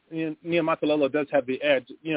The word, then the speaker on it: Niumatalolo: It's Niumatalolo is the pronunciation there. So if you look at it long enough, it actually you pronounce it exactly how it looks.